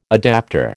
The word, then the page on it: adaptor: American English